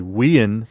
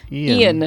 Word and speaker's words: Ian: Yeah, because he would say Wean. – Wean McShane. – Instead of Ian.